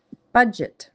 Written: budget

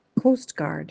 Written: coastguard

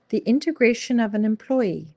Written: The integration of an employee

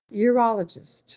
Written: urologist

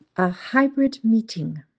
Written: a hybrid meeting

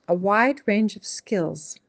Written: A wide range of skills

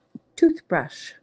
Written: toothbrush

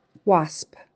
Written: wasp

